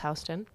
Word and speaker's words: Houston: So, Charles Houston, which is spelled like Houston, but I watched a documentary, so I know it's Houston, had grown up climbing in the Alps with his father.